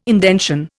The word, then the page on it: indention: Indian